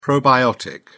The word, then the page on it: probiotic: English; British English